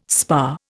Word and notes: spa: Indian

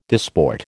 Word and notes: disport: American English

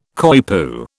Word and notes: coypu: British English

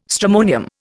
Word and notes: stramonium: Indian

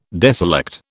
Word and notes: deselect: British English

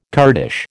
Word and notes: Kurdish: American English

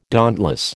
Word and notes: dauntless: American English

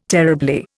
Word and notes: terribly: Indian

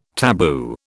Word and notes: tabu: British English

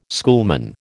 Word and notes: schoolman: American English

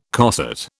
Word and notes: coset: British English

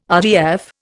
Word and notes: RDF: Indian

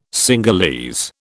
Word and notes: Cingalese: British English

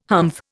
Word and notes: humph: Indian

